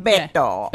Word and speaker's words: Beto: I don't know whether I'm pronouncing that. – Beto. – Beto. Perez. Yeah, there we go.